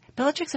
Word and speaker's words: Belatrix: Belatrix, am I saying it right? – Yes, you're saying it right.